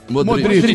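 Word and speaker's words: Modrić: Modric.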